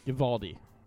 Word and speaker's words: Uvalde: A week after the elementary school shooting in Uvalde, Texas. – Uvalde.